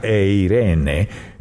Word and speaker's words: eirene: La palabra paz en el griego es una palabrita que se pronuncia eirene y significa unir.